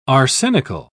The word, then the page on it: arsenical: British English